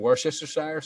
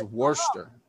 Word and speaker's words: Worcestershire: Okay, then you say freaking Worcester sauce. – You mean Worcestershire sauce? – Yeah, that's not how you pronounce it. If you went to Worcester and said Worcestershire, you'd get fucking beat up and stabbed.